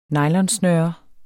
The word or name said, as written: nylonsnøre